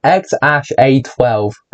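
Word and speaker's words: X Æ A-12: Apparently, Not Must says the name is pronounced X Ash A 12. Hot, great name right there. Pretty much just look at AE. Is a character used in some languages, including Danish and Norwegian. It's often pronounced as Ash, but some people pronounce each letter saying A-E.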